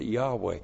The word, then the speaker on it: Yahweh: If you actually pronounced it, you would pronounce it Yahweh.